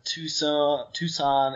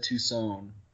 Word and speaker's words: Tucson: I almost said Tucson. – Tucson. – Is that how they say it over there? Or? – No, it's absolutely not. – Okay. There's only one sun, right? Not two suns.